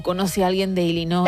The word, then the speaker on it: Illinois: Él, español, ella estadounidense, concretamente de Illinois, y fue quien grabó este audio para que escuchemos cómo se dice realmente. – Aquí te doy la vuestra. – Illinois. – Vamos a poner la parte corta. – Illinois. – No Illinois. Bien.